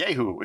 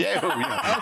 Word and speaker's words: Jehu: It's Jehu. And here's what happens. – Spell it for us. – J-E-H-U. And right. In Hebrew, it'd be Yehu. It'd be Yehu.